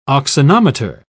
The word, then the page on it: auxanometer: British English